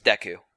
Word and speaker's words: Deku: To put perspective, I've seen it in katakana as Deku. – Well, there you go. – So that's the Japanese pronunciation, so if you want to assume that it's the same in English...